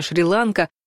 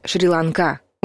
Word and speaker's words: Шри-Ланка: Распространенный вариант произношения Шри-Ланка вновь не совпадает с нормативным. Верно говорить «Шри-Ланка». Ударение на последнем слоге сохраняется и во всех падежах.